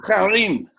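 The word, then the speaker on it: Herem: Haran, is that the way to pronounce it? – Yeah. – That more like Harim. Harim.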